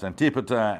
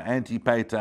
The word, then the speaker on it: Antipater: and sometimes spelled, you know, pronounced Antipater, Antipater, Antipasta. You can pronounce that a number of different ways.